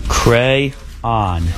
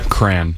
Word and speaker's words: crayon: Cran. – Cran. – Cran. – I pronounce it cran, but it is crayon.